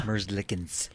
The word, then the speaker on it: Merzlikins: Merzlikins.